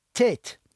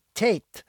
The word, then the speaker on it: Tayt: And it's pronounced Tate, T-A-Y-T, Tate.